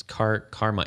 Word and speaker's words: Karman: That one is, it's Carmen. It's spelled K-A-R-M-A-N.